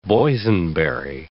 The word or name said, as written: boysenberry